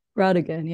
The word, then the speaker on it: Brautigan: And this is inspired by the works of author Richard Brautigan. Am I pronouncing that right? – Brautigan, yeah.